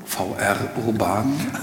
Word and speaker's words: VR Urban: Und 2008 haben Sie in Berlin das Medienkunstkollektiv, ich bin nicht ganz sicher, ob es Englisch oder Deutsch aussprechen soll, VR Urban, VR Urban. – Englisch ist besser, ja.